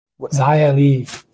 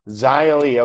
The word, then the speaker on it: Xia Li: Is it Shia Lee? – Xia Li. – Xia Li. Okay, cool. Thank God. I knew I was dead wrong.